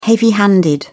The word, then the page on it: heavy-handed: British English